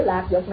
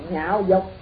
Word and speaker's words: lạc dục: Chữ lạc dục này có chỗ đọc cũng là nhạo dục, chữ lạc có chỗ đọc là nhạo, nhạo là ưa.